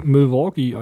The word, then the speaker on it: Milwaukee: Aus Milky Way, oh. Ja. Ach, ja. Milwaukee, Alter.